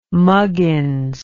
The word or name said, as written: muggins